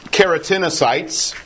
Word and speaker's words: keratinocyte: Most of them, by far most of them, are keratinocytes, which is one way you can pronounce that. Literally a keratin cell, right? Because they produce a lot of keratin, which is strong protein. You can also pronounce that as keratinocyte. Either one is acceptable.